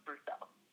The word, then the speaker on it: Brousseau: My name is Henry Burke Brousseau, HENRYBERGBROUSSEAU, and it's pronounced Henry Burke Brousseau.